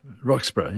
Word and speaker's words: Roxburgh: Roxburgh, yeah.